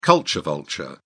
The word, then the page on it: culture vulture: British English